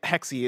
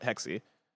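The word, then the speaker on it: Hexe: Somebody in chat said, bless the, I think it was WB Buck in chat, said Assassin's Creed Hexy is pronounced Hex, like a witch thing. And that's what I thought. I originally was pronouncing it Hex, but I talked to somebody from Ubisoft and they were pronouncing it Hexy.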